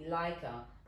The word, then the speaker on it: Laika: So there's only one person I know from as a teenager who used to call me Laika, but that's thinking of my name and the way it's spelled, L-A-I-K-A.